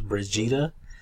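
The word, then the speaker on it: Brigitte: Her name is, looks like it's spelled like Bridget, but it's pronounced Brigida.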